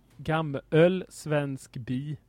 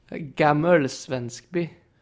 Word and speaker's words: Gammelsvenskby: Men eh, jag har faktiskt den lokala dialekten här så uttalar man det eh, ga gammels, gammöl svensk bi. Gammelöls. – Gam Gammelsvenskby. – Gammelsvenskby.